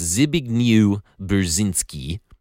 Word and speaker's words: Zbigniew Brzezinski: It's Zbigniew Brzezinski.